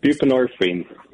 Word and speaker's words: buprenorphine: And you're going to talk about this uh, opioid, if I understand this, uh, bu- Is that I say that right? Bupref- how do you buprenorphine. – Buprenorphine. I'm sorry.